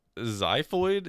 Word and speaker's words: xiphoid: Our X word is xiphoid, is how I would pronounce it. It is X-I-P-H-O-I-D. I'm not going to lie. I never really looked into if there are other rules for X's. I learned that it sounds like a Z in xylophone, and I was like, that's good enough forever.